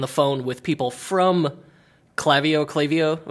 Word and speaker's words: Klaviyo: By the way, I found out today that Klaviyo is actually pronounced Klaviyo.